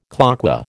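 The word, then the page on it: cloqué: American English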